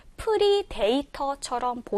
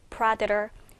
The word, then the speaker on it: predator: 발음이 생긴 것대로 철자대로 발음을 하게 되면 프리데이터처럼 보이지만 이 단어의 발음은 predator, predator, predator로 발음이 되기 때문에 우리 친구들 꼭 기억하면 좋겠습니다.